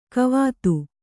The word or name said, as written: ಕುವಾದಿ